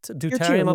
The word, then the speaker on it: deuterium: Was it deuterium? Is that how you say it? Deuterium abundances? – Deuterium, yeah.